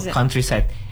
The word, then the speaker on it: countryside: Tapi actually there's a better way to pronounce it that can be interpreted as it's better way. – Okay. Okay. – For example, countryside. You don't really have to read the U like count. You can just say ka, like countryside.